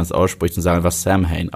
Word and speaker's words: Samhain: deswegen Samhain, ja, das schreibt man eigentlich mit einem M und einem H, und einem H also S-A-M-H-A-I-N, okay? Wie Samhain, mhm.